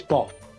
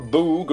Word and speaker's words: Baugh: It's spelt, yeah, B-A-U-G-H, but I think it's Boff. – I would have said Bow, but it's not Boog and it's not Buff, is it? – I think I made the same mistake.